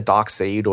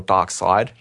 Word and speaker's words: Darkseid: And the character Darkseid, or Darkseid, depending on how you want to pronounce it. – How do you spell it? – It's S-E-I-D.